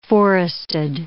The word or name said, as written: forested